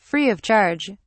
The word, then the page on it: free of charge: British English